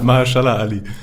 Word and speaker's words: Mahershala Ali: Man muss schon sagen, die dritte Staffel fand ich schon, war, ich glaube, mit Mahershala Ali? Nein, wie spricht man den aus? – Ja, das war Close Enough. – Mahershala Ali.